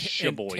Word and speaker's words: Sheboygan: And the note said, Will the person who finds this bottle return this paper to George Moreau, Choiboygan, Michigan? – I think and, it's Sheboygan. And tell where it was found. How do you say it? Sheboygan. Sheboygan, okay and it was dated November 1926.